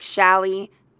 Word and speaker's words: challis: I've heard some people with the accent on the first um, syllable, like Shally, or, you know, but I would think it's pronounced Shali, so who knows?